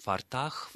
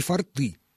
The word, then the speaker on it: форты: То, что касается фортов, то перед нами пример слова, у которого во множественном числе ударение всегда ставится на окончание. То есть фортах, фортами, и так далее. И форты, да, даже во множественном числе в именительном падеже на окончании.